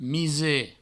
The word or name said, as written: Mise